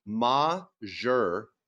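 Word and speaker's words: majeure: Okay, Joe, a Navy helicopter pilot, says the proper way to say it is majeur. That's M-A-H-dash-jeur.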